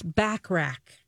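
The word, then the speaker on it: Bachrach: E-B-O-N. Ebon? – I didn't know that. – Moss Backrack, like Burt Backrack.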